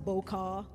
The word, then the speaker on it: Boqer: It is the season of our Bokar, spelled B-O-Q-E-R, pronounced Bokar. Because I feel like people are going to be butchering this.